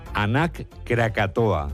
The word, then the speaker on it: Anak Krakatoa: Nos vamos a Indonesia, donde la erupción del volcán, vamos a ver si lo decimos bien, Anak Krakatoa. – ¿Lo he dicho bien? Sí, parece euskera, pero es indonesio.